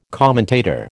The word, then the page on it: commentator: American English